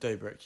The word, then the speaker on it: Dobrik: Dobrik. How do you say that? Dobrik, yeah.